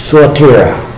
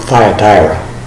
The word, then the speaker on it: Thyatira: And when you look in the Greek, I went through my Greek, the lexicon under the letter U, it's always pronounced who in the beginning of a letter, of a word. And later on it's an oo, like our long U. So Thyatira, not Thyatira.